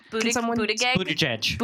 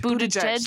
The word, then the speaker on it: Buttigieg: Well, How do you pronounce Pete Buttigieg? – Buttigieg. – Buttigieg. – Thank you so much.